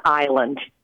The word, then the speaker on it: island: why we don't pronounce the s in island Wow, this is great.